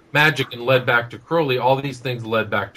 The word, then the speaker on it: Crowley: Uh, Crowley, by the way, is pronounced Crowley, rhyming with holy. That's the way he rhymed it in uh, one of his poems called The Beetle.